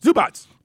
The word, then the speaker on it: Zubac: Is it Zubac, the guy from the Clippers? – Zubac.